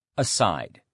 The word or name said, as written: aside